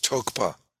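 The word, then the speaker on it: tokpa (རྟོག་པ): Generally speaking, the Tibetan term usually rendered as conceptuality is tokpa. You don't pronounce the R.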